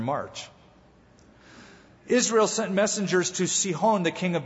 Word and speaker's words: Sihon: Then Israel sent messengers to this character by the name of Sihon or Sihon. Sihon is the right pronunciation.